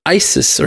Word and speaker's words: Isis: The pronunciation of Isis or Isis in the scene is actually correct.